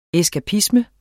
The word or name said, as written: eskapisme